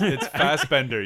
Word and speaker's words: Fassbender: I was going to say, and Fastback, but he's blue. – Yeah, he's not green. – Fastback? – His turtle. – Everybody shut up. – It's Fassbender. You pronounced it wrong.